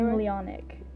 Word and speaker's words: ganglionic: highly modified by ganglionic. – Thank you. Fusion and migration forward towards the head region. The large ganglion? I did not say that right. – Ganglionic.